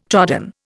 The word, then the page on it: trodden: Indian